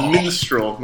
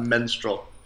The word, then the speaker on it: minstrel: So it's minstrel, not menstrual, for the record.